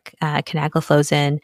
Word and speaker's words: canagliflozin: And the ones that have been studied are canagliflozin, dapagliflozin, and empagliflozin. And the way I remember it is like CDE. Those are the ones that have been studied so far. Canagliflozin, dapagliflozin, and empagliflozin.